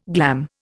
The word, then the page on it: glam: Indian